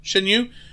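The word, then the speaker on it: Shan Yu: Shen Yu.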